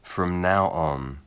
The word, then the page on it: from now on: English